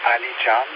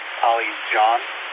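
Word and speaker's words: Alican: My name is Ali John, like Ali John. – Can you spell it? I'm so sorry. – It's Alpha, Lima, India, Charlie, Alpha, November, but it's not Ali Ken, it's Ali John.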